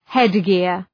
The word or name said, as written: headgear